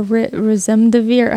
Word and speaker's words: remdesivir: Your Remdesivir, how do you say that? – Remdesivir. Remdesivir yeah.